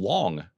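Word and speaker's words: long (龙): Maybe he might say it on TV, but he said, you know, you pronounce dragon long in Chinese. I didn't know that. So long is dragon in Chinese.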